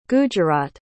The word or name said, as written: gujarat